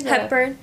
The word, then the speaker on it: Hepburn: She like loves Aubrey Hepburn. Was that how you say it? – Hepburn.